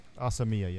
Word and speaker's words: Asamiya: Uh, he said, "Have you read Batman: Child of Dreams by Kia As- Asamiya? Is that how you pronounce it? – Asamiya, yeah. – Asamiya. Okay. Knowing your love for all things Batman and Japanese, it seems the perfect cocktail.